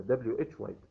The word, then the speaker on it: why: لكن لماذا اللي هو كلمة why، why w h y، هذه حروفها w h y، why ينطق why كأنه حرف الواي ها في النطق، نفس النطق why.